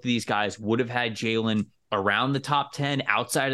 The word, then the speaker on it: Jalen: Mel's got Jalen.